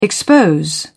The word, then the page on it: expose: British English